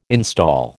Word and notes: install: American English

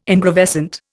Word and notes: ingravescent: Indian

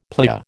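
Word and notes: plié: American English

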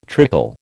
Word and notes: trickle: American English

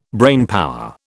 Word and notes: brainpower: British English

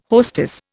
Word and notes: hostess: Indian

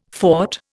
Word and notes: fort: Indian

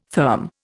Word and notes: therm: Indian

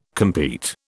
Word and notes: compete: British English

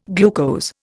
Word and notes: glucose: Indian

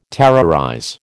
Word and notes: terrorize: American English

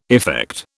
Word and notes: effect: British English

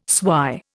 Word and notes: swy: Indian

